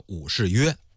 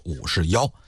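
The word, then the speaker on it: 武士彟: 武则天的父亲叫武士约，有人考证啊，说他父亲武士约的这个约字呢，应该念成是妖，跟那个大约的约有点相近。比方说咱们买东西了，这秤给的够不够分量啊，你再重新给我腰一下，或者说你上秤腰腰，你多少斤了。有专家学者呀考证出来，说这个武则天的父亲呢，应该是读作武士妖，但是我们就用约定俗成的吧。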